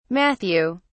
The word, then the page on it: matthew: British English